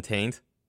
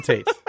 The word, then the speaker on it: Tate: Did you say his name is Golden Taint? – No, Golden Tate.